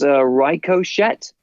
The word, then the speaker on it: Ricochet: So being an expert on the inventors of the English language, what's the proper pronunciation for that name? – I believe it's Ricochet. – Oh, okay. Throw a monkey wrench in there. Hello. – Hey-o. – Hey-o. – That was an email coming through then. – I just got an email from WWE Creative that says the proper pronunciation is... Ach, Liebenstein is in Rickocket. Yeah, Rickocket with a hard emphasis on cock.